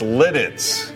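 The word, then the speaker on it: Lititz: Can I ask the producer, did she say that right? – I don't know. That's how it's spelled. – I think you got it right. – It's spelled. – No, it's Letitz. – Letitz. You were in the ballpark.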